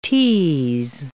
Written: ts

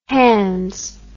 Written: hands

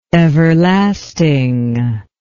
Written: everlastingness

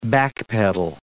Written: backpedal

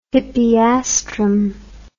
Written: hippeastrum

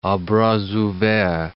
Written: a bras ouverts

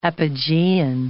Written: apogean

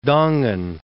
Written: Dongen

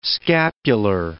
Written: scapular

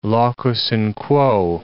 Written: locus in quo

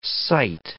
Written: cite

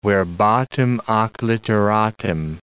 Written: verbatim ac litteratim

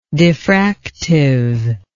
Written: diffractiveness

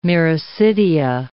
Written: miracidia

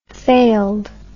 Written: failed

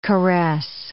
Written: caress

